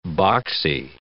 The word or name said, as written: boxy